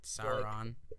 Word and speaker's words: Sauron: I feel like Sauron... I wouldn't. I feel like I'm saying his name wrong. – Sauron. I know a kid that with a similar name than that.